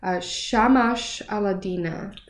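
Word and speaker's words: Shamash Alidina: So, and then at some point in your path, I recall you saying that you then worked with the leading mindfulness expert, Shamash Aladina. – Correct. – Did I say that right? – You did.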